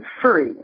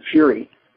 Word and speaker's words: Fure: It's pronounced Fure in Norway. But when my family came to this country, they actually pronounced it Furry, and a lot of my relatives are Furries. My father and his younger brother changed the pronunciation to Fury, which I'm very grateful for because it's a much more powerful stage name.